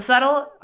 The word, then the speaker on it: subtle: The subtle, es una palabra difícil que decir, porque como ah, que quiero pues pronunciar la B, pero no. The subtle art of not giving a fuck.